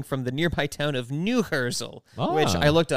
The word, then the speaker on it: Neuherzl: Yeah, it's spelled N-E-U-H-E-R-Z-L. Neuhersel. – Neuhersel.